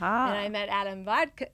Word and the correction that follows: Wodka: Is that how you say his name? – Wodka. – Wodka. Sorry, Adam.